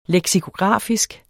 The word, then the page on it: leksikografisk: lεgsikoˈgʁɑˀfisg